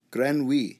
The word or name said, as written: grenouille